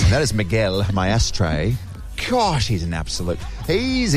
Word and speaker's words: Maestre: And if you're wondering how to say his last name, he explains it. It is my ashtray.